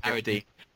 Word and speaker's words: Carradine: know the name of Carradine or Carradine. I'm not sure how to pronounce it. – Carradine. – Okay, I better get it right.